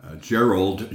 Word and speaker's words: Jerold: It's Gerald, J-E-R-O-L-D. Apps, A-P-P-S, and I mostly go by Jerry.